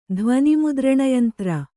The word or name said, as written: ಧ್ವನಿಮುದ್ರಣಯಂತ್ರ